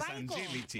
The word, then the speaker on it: Angelici: La pronunciación es Angelici.